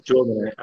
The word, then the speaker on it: Jom: do you pronounce Jome. I often say it rhymes with Rome, right?